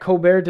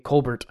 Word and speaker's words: Colbert: It is unsurprising that Colbert did not know that the... Colbert, I think is how it should be pronounced.